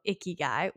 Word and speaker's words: ikigai: But the reason why we were talking about the Venn diagram is because we were talking about ikigai, which I did look at a video to see if I was saying it right. And it seems that that is the way it's said.